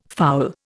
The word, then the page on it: fowl: Indian